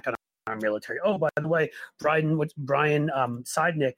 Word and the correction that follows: Sicknick: am I pronouncing his name right? – Sicknick.